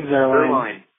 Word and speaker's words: Zuerlein: I also went with Greg Zuerlein. Is that how you pronounce his name? – Yeah. – Greg Zuerlein.